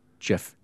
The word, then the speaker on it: GIF: In the documentation for version 8.33 and the facts section, it states the GIF graphics interchange format, pronounced GIF, J-I-F, was designed by CompuServe and the official specification released in June of 1987.